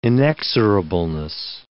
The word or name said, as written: inexorableness